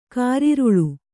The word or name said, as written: ಕಾರಿರುಳು